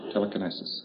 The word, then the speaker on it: telekinesis: But you don't find us using words like telekinesis or telekinesis. That's the proper pronunciation of it. That's a gaudy word, isn't it? Telekinesis.